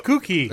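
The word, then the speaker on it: Kookhee: It's a did you just call it a gookie? – No, cookie. – It's spelled K-O-O-K space H-E-E. – Cookie.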